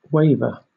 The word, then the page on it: waver: British English